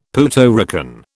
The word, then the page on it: Puerto Rican: British English